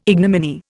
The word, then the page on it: ignominy: Indian